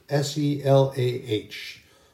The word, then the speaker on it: Selah: Selah, S E L A H. The E is pronounced long, which means it's pronounced as an E, Selah.